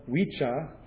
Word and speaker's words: Wicca: Wicca, whose double C is pronounced in Old English as a soft CH, a CH, so thus Wicca, is the origin of the modern English word witch.